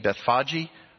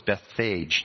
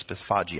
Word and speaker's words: Bethphage: Okay, so Jesus, as he was coming from Bethany and Bethphage over, and that is how it's pronounced, as you're wondering, some of you are saying, oh, I thought it was Bethphage. No, it's Bethphage, actually.